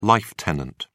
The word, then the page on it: life tenant: British English